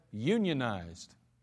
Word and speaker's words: unionized: Well, you ask them to pronounce a particular word that's spelled U-N-I-O-N-I-Z-E-D. It's funny because, watch this now, the labor-conscious plumber, he's going to pronounce the word as unionized. But a chemist would probably say unionized.